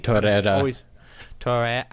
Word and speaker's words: Torreira: Torreira. – I can't roll my R's. Do you have to roll your R's for his name? – I think you can say Torreira, but I always do Torreira.